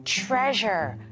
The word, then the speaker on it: treasure: Treasure, 보물이에요. 그래서 이 S를 좀 주의하세요. S, S 발음이 아니라 좀 Z, treasure. 이런 소리가 나죠.